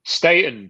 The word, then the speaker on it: Staton: Staton, but it's fine. Everybody says Staton, so it's fine.